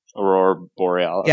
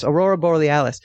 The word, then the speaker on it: Aurora Borealis: One, the Aureus, how do you say that? – Aurora Borealis. – Yes, Aurora Borealis.